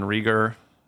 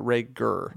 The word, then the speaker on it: Reagor: Apparently, his name is pronounced Jalen Reagor. – Oh. – Like Ray Gunn, but Reagor.